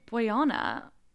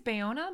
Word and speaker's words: Bayona: Boyona. Did I say that right? – I think it's Bayona, but Bayona. I'm not entirely sure.